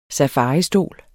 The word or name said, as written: SAFARISTOL